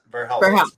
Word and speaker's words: Verhelst: Okay, before we go, the Ver, Ver, Verhelst, you have to tell us what's Verhelst. Am I saying it right? – Yep, Verhelst. – Verhelst. Dutch.